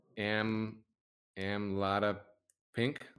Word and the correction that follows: amlodipine: Am Amlada Pink, and uh, Amlodipine. I think it's pronounced amlodipine, but yep. – Okay, amlodipine.